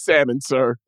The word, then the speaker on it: salmon: See, I told you the L you're supposed to pronounce that bitch. – No, no. It's salmon, sir.